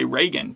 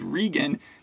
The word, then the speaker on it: Regan: Yeah, and you said it right, because a lot of people would say Reagan, but her name is Regan. She's upper class, so it's like regal.